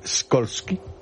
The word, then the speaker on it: Skolsky: ¿Cómo se pronunciaría en polaco Skolsky? – Skolsky. Como tú lo has dicho.